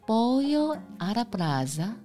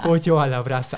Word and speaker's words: Pollo a la Brasa: 对，第一个就是 Pollo a la Brasa。 – 嗯，你的发音很好。 – 对对，有好老师。 – Pollo a la Brasa，我自己讲错。 Pollo a la Brasa。